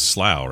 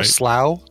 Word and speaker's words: Slough: It was Slough, wasn't it? S-L-O-U-G-H? – Yeah, I think it was Slough, right? – Or Slough? Slough, yeah. Pronounced Slough? – Yeah.